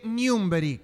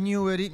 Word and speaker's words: Newbery: Porque se conmemora el nacimiento del célebre profesional argentino Jorge Newbery, que no es Newbery, Newbery. – Ah, Newbery. – Newbery. – Bueno. – Se escribe parecido, pero es Newbery.